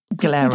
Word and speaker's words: Glera: So I thought Prosecco was made from Glara. Glera, that's right. – However you want to pronounce it.